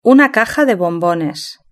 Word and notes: una caja de bombones: Spain Spanish

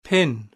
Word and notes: pin: British English

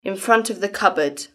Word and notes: in front of the cupboard: British English

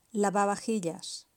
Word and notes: lavavajillas: Spain Spanish

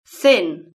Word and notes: thin: British English

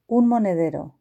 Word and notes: un monedero: Spain Spanish

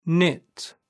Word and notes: knit: British English

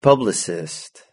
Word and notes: publicist: British English

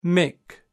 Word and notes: Mick: British English

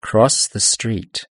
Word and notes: cross the street: British English

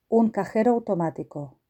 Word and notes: un cajero automatico: Spain Spanish

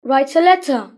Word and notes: write a letter: British English

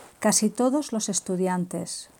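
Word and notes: casi todos los estudiantes: Spain

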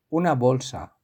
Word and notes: una bolsa: Spain Spanish

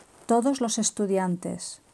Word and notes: todos los estudiantes: Spain Spanish

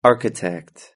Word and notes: architect: British English

